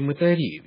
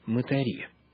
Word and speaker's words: мытари: Возможно, вы, уважаемые радиослушатели, привычны к иному ударению в этом слове. Что делают мытари? Так говорит большинство. Однако, если мы посмотрим на то, как сама Библия ставит ударение в этом слове, мы обнаружим следующее.